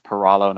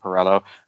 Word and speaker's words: Peralo: First off, my last name is actually pronounced Peralo, not Peralo.